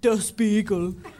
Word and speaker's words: Der Spiegel: Am I saying that right? – The Spiegel. – Oh, The Spiegel, sorry. – Is that Mirror? – Der Spiegel.